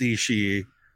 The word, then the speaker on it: Ishii: Against Ishii.